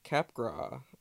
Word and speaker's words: Capgras: But moving on, so there's this also a term called the Capgras delusion, because you gotta have that silent S in there.